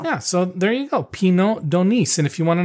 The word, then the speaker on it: Pineau d'Aunis: It's P-I-N-E-A-U, and the d'Onis is D'A-U-N-I-S.